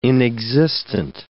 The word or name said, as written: inexistent